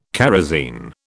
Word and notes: kerosine: British English

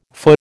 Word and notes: feu: American English

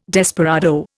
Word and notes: desperado: Indian

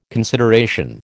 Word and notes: consideration: American English

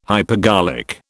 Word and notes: hypergolic: British English

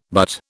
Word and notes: butte: British English

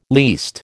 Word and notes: least: American English

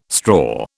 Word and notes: straw: British English

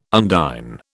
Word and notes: undine: British English

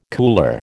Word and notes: cooler: American English

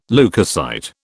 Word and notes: leucocyte: British English

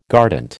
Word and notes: guardant: American English

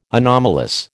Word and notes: anomalous: American English

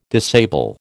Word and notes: disable: American English

